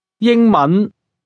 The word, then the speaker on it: 英文: 英文 You've probably noticed that some words seem to end abruptly and that some are pronounced in a higher pitch than others.